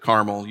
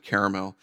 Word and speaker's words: Carmel: I pronounce it Carmel. You might pronounce it Caramel.